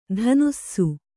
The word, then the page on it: ಧನುಸ್ಸು: dhanussu